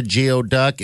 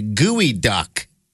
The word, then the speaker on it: geoduck: It's not a geoduck. It's pronounced as a gooey duck.